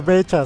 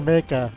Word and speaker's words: mecha: No se dice mecha, se dice meca.